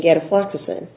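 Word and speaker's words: gatifloxacin: Am I saying that right? – Right. Okay. Gadafloxacin.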